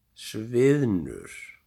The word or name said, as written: Sviðnur